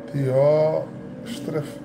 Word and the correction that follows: Diótrefes: Dióstrefes, Diófre, eita gente, a pronúncia é Diótrefes, porque em português fica Diótrefes, mas em grego é Diófrestes, homem ambicioso de poder, não nos quer receber.